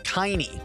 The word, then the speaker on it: Kiney: Executive producer is Dave Kiney, rhymes with shiny.